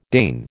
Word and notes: deign: American English

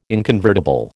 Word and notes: inconvertible: American English

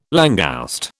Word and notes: langouste: British English

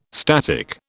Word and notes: static: British English

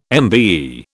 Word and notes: MBE: British English

